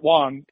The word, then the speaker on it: Wang: So if anyone goes to just, you know, at Garrett Wong, get W-A-N-G, and I pronounce it like an O instead of right. – A. I try to pronounce it correctly.